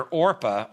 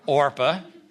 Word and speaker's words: Orpah: And by the way, I understand that Oprah was named after Orpah, only her mother didn't know the proper pronunciation. So that's how she got her name. So even her name's a mispronunciation of what's in the Bible. Orpah was her name.